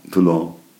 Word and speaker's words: Toulon: Toulon.